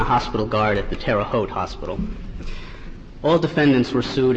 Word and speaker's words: Terre Haute: the Chief Medical Officer of the Terre Haute Prison Hospital. – Perhaps as just as an ex-Midwesterner, it bothers me, it's pronounced Terre Haute.